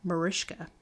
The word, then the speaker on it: Mariska: The only real correction I have is that Jane Mansfield's daughter and the star of Law & Order, SVU, pronounces her first name Mariska.